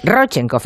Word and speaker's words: Rodchenkov: Bueno, por cierto, dice Jordi que no se dice Rodchenkov, que es Rodchenkov. Me corrige. Rodchenkov, ese es drújula la palabra, el apellido.